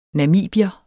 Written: namibier

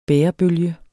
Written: bærebølge

